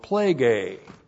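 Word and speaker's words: Plague (πληγή): And this is the preposition N plus the dative, plural of plague. Again, N plus the dative of sphere, in the sphere of plague. P-L long E-G long E. Plague.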